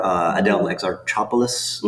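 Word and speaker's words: Adèle Exarchopoulos: Adele Exarchopoulos, mm-hmm.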